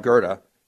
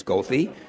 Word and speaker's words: Goethe: I picked Johann Wolfgang von Goethe because there's a street by his name in my ward that the CTA bus drivers routinely mispronounce as Goethe, but it is Goethe.